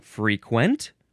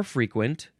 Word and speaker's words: frequent: First form, let's see, you can say, frequent or frequent, emphasize either syllable.